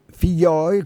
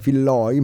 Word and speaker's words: Figlioi: Figlioi, come lo hai chiamato con la pronuncia giustamente argentina, magari diciamo come è scritto anche Filloi, ma mi piace di più Figlioi